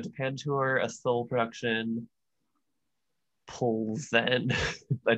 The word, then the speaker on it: Pilsen: Pilsen is how you pronounce it. It's west of Prague, so it's like, yeah.